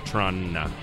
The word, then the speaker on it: Toronto: The way to spell the correct pronunciation of Toronto is C-H-R-A-N-A. – Yeah. – Maybe a double N at the end, like Toronto.